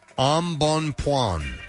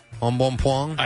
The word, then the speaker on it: embonpoint: E-M-B-O-N point, P-O-I-N-T. So E-M-B-O-N point. I was going to pronounce it embonpoint, but it's embonpoint.